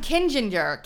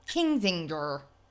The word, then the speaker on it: Kinzinger: Adam Kinzinger. – Kinzinger. – Just add it to my mispronunciations.